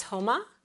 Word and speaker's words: Huma: It actually is pronounced properly as Huma, H, like a short U, but most people have a hard time pronouncing it. So I absolutely respond. Well, I respond to both Huma and Huma and Uma. I pretty much respond to everything, but the proper pronunciation is Huma.